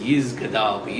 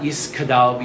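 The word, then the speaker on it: yisgadal: But it's not, it's Yis, but don't make sure that the teeth do not go from a hard S to a hard G, which would be a K. It's not Yiskadal, Yiskadash. That's what you're hearing everyone saying.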